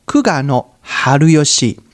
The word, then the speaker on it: 玖峨晴嘉: 在日本古时被称为天才的阴阳师九二秦家，日文的发音叫做 Kugano Haruyoshi，被自己最信任的朝廷给背叛了，而且也遭人杀害。